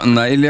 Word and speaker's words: Neil: Ja, det var bare jeg som uttalte det litt dårlig. – Ja, Neil, ja.